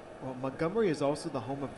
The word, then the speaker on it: Montgomery: Okay, so don't hit the T hard and say the first syllable like a U sound. Montgomery.